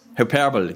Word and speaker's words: Hyperbole: Aber dieses Wort will ich, die Aussprache davon. Hyperbole. Hyperbole, genau. Hyperbole, das war das Wort.